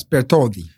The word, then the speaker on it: Bartholdi: Yes, Bartholdi.